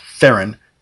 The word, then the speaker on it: Theron: and what Andy that's the Charlize Theron character or Theron, that's how you pronounce it, Theron,